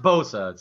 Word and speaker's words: Bosa: It is pronounced Bosa, so that might be part of the boss, problem.